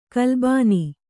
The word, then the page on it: ಕಲ್ಲಿಬೋನ: kallibōna